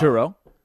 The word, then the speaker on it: churro: I think it's churro.